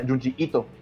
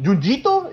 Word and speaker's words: Junji Ito: Se pronuncia Junji Ito. Y muchos me dicen, ¿Junjito? Yo, no, Junji Ito.